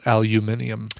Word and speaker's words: aluminium: I would like America to adopt the King's English pronunciation and call it aluminium.